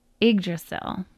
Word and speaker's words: Yggdrasil: How to say it? In the Norse language, the world tree was named it's Yggdrasil, Yggdrasil.